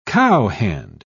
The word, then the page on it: cowhand: British English